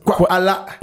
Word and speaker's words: Kwala: La deuxième association, c'est Kwala. Ça se prononce Kwala, qui veut dire en langue.